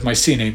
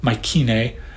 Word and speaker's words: Mycenae: Some people pronounce the C in Mycenae hard, like a K, Mycenae, or you may hear the Y pronounced as a short I, Mycenae, and so on.